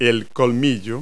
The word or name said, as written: tusk